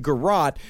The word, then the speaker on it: garrote: How is this uh, pronounced? Garrote. It's garrote. A garrote, yes, because God forbid I mispronounce something.